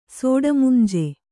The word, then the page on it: ಸೋಡಮುಂಜೆ: sōḍamunje